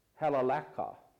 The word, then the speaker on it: halalaka: And the word in the Chaldean language is pronounced halalaka, which means fine promises.